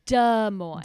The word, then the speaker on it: Des Moines: Did I say that right? Des Moines. – They say Des Moines. Des Moines. I think. – Des Moines. – But if we were being French, it would be Des Moines.